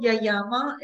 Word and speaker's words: Yayama: Is it Yamama? Did I say that right? – It's called Yayama.